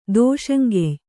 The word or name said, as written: ದೋಷಂಗೆಯ್